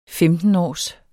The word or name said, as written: femtenårs